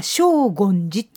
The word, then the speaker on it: 荘厳寺: おー、荘厳寺と言いますが、漢字で書くと荘厳な響きとかという荘厳という漢字を書いて、読み方は荘厳寺っていうんですね。